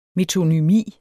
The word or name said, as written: metonymi